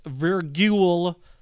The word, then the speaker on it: virgule: I might be pronouncing it wrong. V A R G U L E. Virgule. It's a not it's a tough word.